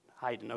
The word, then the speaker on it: Haydn: Haydn, okay, I got it right.